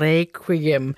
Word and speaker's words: requiem: Vi kunne sagtens synge med flade danske ager, siger han, men vi synger med flotte, runde ager, og vi ruller på ageret og synger requiem med den latinske QU-udtale. Og samme italienske udtale bruger man altså også i de engelsktalende lande.